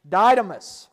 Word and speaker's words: Didymus: One of the 12 called Didymus, or Sister Sarah, what's the pronunciation? Didymus, thank you.